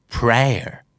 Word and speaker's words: prayer: สองคำนี้ออกเสียงว่า mayor กับ prayer ไม่ออกเสียง a แต่เป็นออกเสียง r นะครับแล้วท้ายคำออกนิดเดียวบางทีแทบไม่ได้ยินฟังเผินๆเราอาจจะได้ยินเขาออกเสียงว่า mae กับ prayer ด้วยซ้ำไปนะครับ mayor prayer